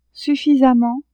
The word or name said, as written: suffisamment